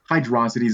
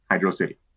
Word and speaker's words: Hydro City: And no, it's not pronounced Hydrosity Zone. – Okay, guys, I know you're all spamming down my front door. With Hydrosity. It's not Hydrosity. Hydro City.